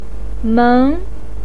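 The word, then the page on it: meng2: pinyin; tone 2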